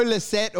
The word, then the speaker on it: Lycett: And it's what happened with that Joe Lycett or whatever.